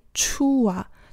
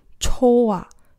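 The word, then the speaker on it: 抽 啊 (좋아): 那除了这个连读的轻化以外呢，啊，还要强调的是这个不要念成出啊，就是不要念成一个乌的音，而是要念成它原本的这个元音的音应该是 o，也就是抽啊，而不是出啊。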